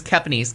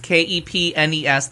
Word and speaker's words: Kepnes: And her last name is Kepnes, Kepnes.